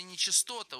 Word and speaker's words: нечистота: Господин мистер Новиченко, в слове «нечистота» ударение на «а». Нечистота, а не частота.